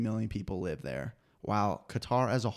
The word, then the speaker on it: Qatar: Did I say Qatar again? Wait, that's how you pronounce it. – That's right.